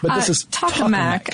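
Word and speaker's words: tokamak: Is pronounced tachomac? – Yeah, I keep thinking taco.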